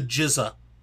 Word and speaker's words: GZA: Is that how it's pronounced? By the way, it's pronounced the jizza.